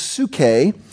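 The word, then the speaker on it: psuche (ψυχή): But in the Greek, the word is psuche. Psuche, we would spell it, we've got it in English as psyche, your psyche.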